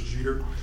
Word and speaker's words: Jeter: Hello all, that's Judge Chris Jeter, Searchlight Justice of the Peace, Searchlight Justice Court, and my last name is spelled J-E-T-E-R.